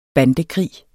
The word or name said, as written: bandekrig